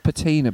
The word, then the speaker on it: patina: Have you ever heard the word patina before? – No. – I believe it's pronounced patina. P-A-T-I-N-A. – Okay. – I assume it's patina, not patina. Maybe there'd be a double N if it was patina.